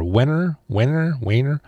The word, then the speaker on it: Wenner: Jan Wehner, W-E-N-N-E-R, Wehner, careful.